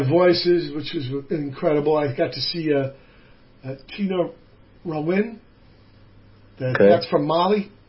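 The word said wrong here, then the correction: Tinariwen: Oh, Tanariwen. – Is that how you say it? – Yeah, Tanariwen. Yeah, that's Tanariwen. Heavy.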